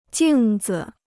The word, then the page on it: 镜子: jìng zi